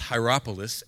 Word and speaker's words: Hierapolis: It's actually pronounced Hierapolis, and you can go there today.